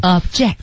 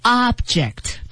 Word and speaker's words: object: Object, 这是做动词的时候。当然大家一定也知道了。它还有一个读音啊，叫做 object。 – 对。那您仔细听哈，做动词的时候是 object, 而做名词的时候是 object。也就是说前面的重音往前提，而且后面那个 J-E-C-T 那个 E 要读成一个小 E 里。